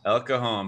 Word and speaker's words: El Cajon: What, El Cajon? Is that how it's pronounced? – El Cajon,